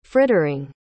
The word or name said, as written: frittering